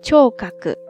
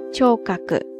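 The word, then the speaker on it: 聴覚: 然后视觉呢是 shikaku shikaku shikaku，听觉呢是 chokaku chokaku chokaku ですね。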